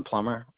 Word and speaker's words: Plummer: First of all, my name's Aaron Plummer, A-A-R-O-N-P-L-U-M-M-E-R.